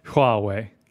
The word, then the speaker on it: Huawei: And she basically Huawei. Yeah, that's how you say it.